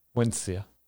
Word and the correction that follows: Wensicia: Wensia? – Yeah. I have always read that as Wensia. – Yeah, it's Wensicia.